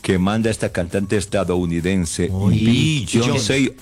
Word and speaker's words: Beyoncé: ¿Cómo se pronuncia? – Sí, sí, sí, Beyoncé.